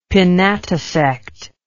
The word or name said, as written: pinnatisect